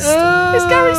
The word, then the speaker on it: Garry: It rhymes with Harry, you spell it Gary.